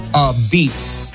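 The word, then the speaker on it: apizza: Pronounced a beets. A beets.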